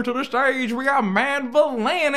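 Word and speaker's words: Madvillainy: It's Mad Villainy. Yeah. – Okay. I've been saying that wrong this whole time. – What were you saying? – Mad Villainy.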